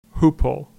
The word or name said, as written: Hooppole